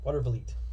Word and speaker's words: Watervliet: Watervliet.